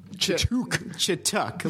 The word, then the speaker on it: Chituc: Chituc, like chicken tuck. No one in the history of the universe has ever pronounced my name right. It's okay. – What is it? – Chituc. – It's like chicken tuck. Chituc.